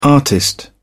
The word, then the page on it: artist: British English